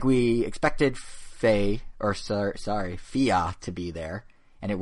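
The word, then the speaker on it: Fe: Nintendo called it Fei, but then in a Twitter video by the developers at Zoink, they all pronounced it Fia. So I don't know where the awe comes from, but it's apparently Fia.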